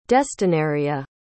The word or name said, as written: destinarea